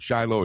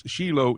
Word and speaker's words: Shiloh: Now, you may pronounce it Shiloh here in America, but it's Shiloh in Hebrew.